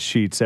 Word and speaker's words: Scheetz: Well, I'm at Chris Sheets. Everybody spells my name wrong. S-C-H-E-E-T-Z.